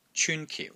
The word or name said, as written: CYUN1 KIU4